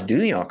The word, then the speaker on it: Duhon: Way to pronounce Duhon correctly.